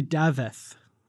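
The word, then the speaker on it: Dafydd: I think it's actually Daveth, I believe. – There's a thith? – Yeah, it's a Welsh name, I believe. – Okay, Daveth Abhugh.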